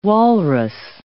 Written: walrus

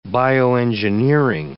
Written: bioengineering

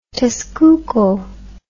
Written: Tezcuco

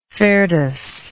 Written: ferdus

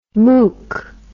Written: mook

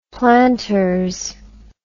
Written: planters

